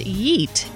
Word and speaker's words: yeet: Yep, and the next word is yeet. It's spelled like the word beet, only with a Y at the beginning instead of a B.